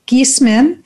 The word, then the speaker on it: Giesemann: I am so excited about today's guest, Suzanne Giesemann.